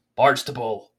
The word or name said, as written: Barnstaple